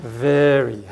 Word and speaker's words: very: V の発音は蜂が長く飛んでる感じ。下唇を噛んで、 Very、はい、 Very。